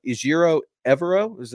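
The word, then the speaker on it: Ejiro Evero: Well, it's completed officially after this game, and you get your defensive coordinator, and I'm going to butcher his first name. You're going to help me out. Ijiro Evero? – I think he nailed it.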